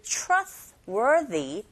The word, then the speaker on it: trustworthy: Trust에서 t 사운드, r 사운드 붙어 있네요. 이런 경우 t가 아니라 ch. Trustworthy. 뒤에 나오는 th는 the, 여기서 the, worthy라고 발음하시면 되고, partner라가 아니라 partners, partners, 아 부분에 강세 주시면 됩니다.